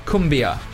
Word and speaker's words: cumbia: but tell me a little bit about cumbia. – Well, Uruguayans grew up with cumbia, actually. Yeah. So, yeah, it's kind of like... – First of all, you pronounce it correctly.